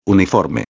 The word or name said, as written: uniforme